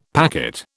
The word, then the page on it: packet: British English